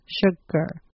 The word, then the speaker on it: sugar: For sugar, it's spelled G-A-R at the end, but you want to pronounce it like G-E-R, sugar.